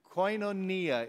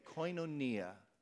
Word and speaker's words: Koinonia: Koinonia is how you say it. Koinonia.